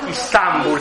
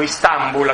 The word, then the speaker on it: Istanbul: Istanbul, si dice in turco, Istanbul, accento sulla Istanbul, che è questa, vedete?